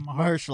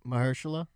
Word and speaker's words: Mahershala: Mahershala Ali. – Say it again. – Mahershala. – Mahershala. – Yeah. Mahershala. – Mahershala. – Ma. – Ma. – Her. – Her. – Sha.